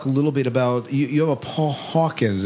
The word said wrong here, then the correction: Hawkinson: Is that am I saying the name right? The Paul Hawkins Mysteries. – Paul Hawkinson. – Oh, thank you, Paul Hawkinson Mysteries.